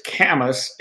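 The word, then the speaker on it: Kamas: Am I mispronouncing it? – She's asking you about the town in Utah. – Yeah, so it's Camas.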